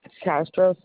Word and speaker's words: Castro: Maria Castro. – Spell that. You said Maria? – Lori? – No, Maria, M-A-R-I-A. – Okay. – Castro, C-A-S-T-R-O. – You said C-R. – No. C-A-S-T-R-O.